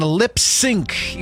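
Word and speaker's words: Lipps Inc: Greatest Hits 98.1 and Lip Sync, even though it looks like Lips, comma, Incorporated. You pronounced it Lip Sync, as into, you know, move your mouth to a song, which is odd.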